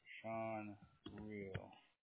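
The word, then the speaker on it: Shawn Rheal: Sean Real. Did you spell my name correctly? – Sean, S-H-A-W-N, and Real is R-H-E-A-L.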